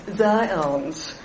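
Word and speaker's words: Zając: His parents were Jewish, and Henry's surname was originally spelled Z-A-J-A-C, Polish for hair. It is pronounced in Polish as Zions.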